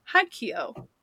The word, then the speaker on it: Haikyuu: And Kazuma from Haikyuu. So... – Oh, Haikyuu! I love Haikyuu. I know. Oh, there you go. That's how you pronounce it.